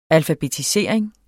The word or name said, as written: alfabetisering